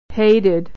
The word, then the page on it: hated: American English